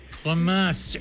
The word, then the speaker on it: fromage: Fromage. – Fromage.